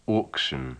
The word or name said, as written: auction